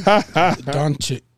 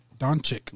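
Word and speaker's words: Dončić: Doncic. – Doncic.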